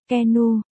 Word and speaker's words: Keanu: Keanu Jack Reeves, phát âm là Keanu, sinh ngày 2 tháng 9 năm 1964, là một diễn viên Canada.